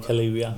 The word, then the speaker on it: Kaluuya: men det är Daniel Kalu, Kaluja? – Eh, Daniel Kaluuya. Kaluuya. ja.